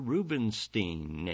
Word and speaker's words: Rubinstein: And since Leonard was now in the classical music business, the natural linkage was with the great Polish pianist Arthur Rubinstein, and Stein is how it's pronounced.